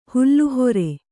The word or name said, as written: ಹುಲ್ಲುಹೊರೆ